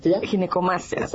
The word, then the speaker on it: Ginecomastia: Ginecomastia. – Sí, exacto. Ginecomastia, ok.